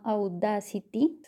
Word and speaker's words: Audacity: Mira, hay un programa que se llama Audacity, se dice así tal cual como lo he mencionado, se escribe Audacity.